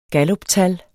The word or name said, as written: galluptal